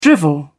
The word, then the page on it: drivel: British English